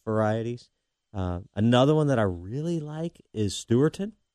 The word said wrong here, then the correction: Stewartonian: Oh, Stewartonian. – Yes, I'm sorry. I said it wrong.